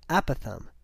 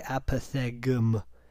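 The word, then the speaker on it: apothegm: Apothem, really weird. A-P-O-T-H, okay, pretty good. Apoth, E-G-M. There's a G in there. You do not pronounce it. Apothem. It looks like apothegum, but it's apothem. A terse, witty saying. Prevaricate. P-R-E-V-A-R-I-C-A-T-E, prevaricate, to deliberately avoid the truth, to mislead.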